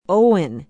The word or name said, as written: owen